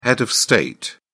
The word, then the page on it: head of state: British English